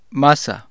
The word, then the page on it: masa: Spanish